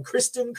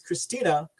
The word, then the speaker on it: Kristina: They even pronounced her name here wrong, Kristen Karamo. They said, but it is Kristina Karamo, not Kristen.